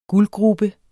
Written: guldgrube

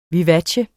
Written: vivace

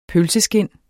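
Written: PØLSESKIND

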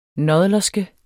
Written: nådlerske

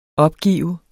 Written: opgive

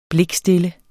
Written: blikstille

